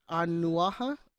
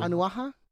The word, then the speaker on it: Onuoha: O N U O H A. Onyoha. – O N U. – Onyoha. – O H A. – Yeah, Onyoha.